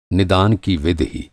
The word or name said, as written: निदान